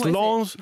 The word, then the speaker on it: Lens: Could you remember Lens? Yeah, probably. – Well, you've mispronounced it, but sorry. – We're going to give it to you. – Yeah. It's Lens, Lens, Spurs, QPR, St Etienne, and he finished his career at Metz.